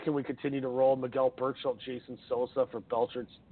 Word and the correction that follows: Berchelt: Belchert's uh, Berchelt I don't know why I couldn't say that correctly.